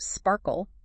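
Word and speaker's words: SPARQL: It relies on a powerful query language called SparkQL, and it's pronounced Sparkle.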